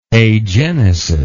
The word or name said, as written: agenetic